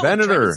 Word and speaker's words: Venator: how to of, pronounce is now is it is it Venator 2000? – No, Venator. It's, it's, Ven- like, it's senator. like senator.